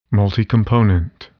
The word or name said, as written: multicomponent